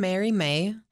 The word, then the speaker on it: Mary Mae: So my personal artwork is under the tag Alien Mary Mae.